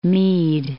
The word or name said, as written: Mead, Lake